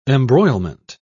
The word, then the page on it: embroilment: British English